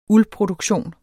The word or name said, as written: uldproduktion